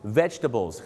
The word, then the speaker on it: vegetable: vegetable vegetable ไม่ใช่ vegetable นะครับ vegetables ครับผม vegetables เขาจะไม่ออกเสียงตัว jet